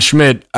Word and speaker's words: Schmidt: And obviously, three of the lads in it, Nick Winston and Smith Winston. I messed up my funny pronunciation of an S there. Nick Winston and Schmidt, we can very easily sort of attach those sort of archetype of characters to our little trio.